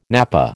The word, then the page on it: napa: American English